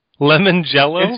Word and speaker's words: limoncello: Limoncello? – It's pronounced Limoncello. – this was limoncello. – Oh, an Italian thing.